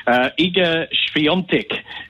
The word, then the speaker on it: Iga Świątek: I'll go, that's how you probably would say it, but yeah, tennis names are damn difficult, Kane, but Iga Świątek is how you pronounce it exactly.